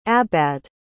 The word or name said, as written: Abbaad